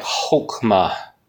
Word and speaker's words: chokmah: But it's C-H-O-K-M-A-H. It sounds like chokmah. It's the best way I can pronounce it. So it's C-H-O-K-M-A-H, chokmah.